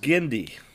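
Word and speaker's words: Genndy: And this week on our certain point of view, we are talking Gennady Tartakovsky's The Clone Wars. – I think it's pronounced Gendy. – All right, fine. – There's no vowel between the N and the D. It's Gendy. Sorry. – Or is it Gennady? Gennady. – It's G-E-N-N-D-Y.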